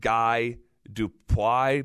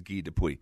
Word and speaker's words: Guy Dupuy: So phonetically, it's like Guy Dupuy, but really he's French, and the way he says it is Guy Dupuy.